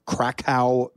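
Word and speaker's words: Kraków: And now, DJ Cam B, you, like, are competing in the Red Bull Freestyle World Finals in Krakow, Poland. Did I say that right? – Yep. Poland.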